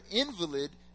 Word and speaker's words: invalid: That's because the word invalid, the definition of the word invalid means a person who has been made weak or disabled by injury or illness when it's pronounced invalid.